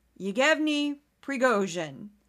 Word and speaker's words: Yevgeny Prigozhin: Yevgeny Prigozhin.